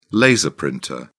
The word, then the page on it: laser printer: British English